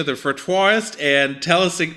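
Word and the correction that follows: frottoir: It's the pronunciation is fratois, which fratois. is French for rub board.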